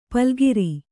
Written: ಪಲುಗಿರಿ

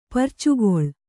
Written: ಪುರ್ಚುಗೊಳ್

